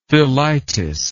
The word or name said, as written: thelitis